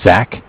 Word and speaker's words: Zach: My name is Zach, spelled Z-A-C-H. – Mm-hmm. – And I'm from Bank of Cardiff. Cardiff is spelled C-A-R-D-I-F-F.